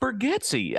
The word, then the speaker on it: Bargatze: It's pronounced Bergetsky.